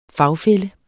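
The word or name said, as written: fagfælle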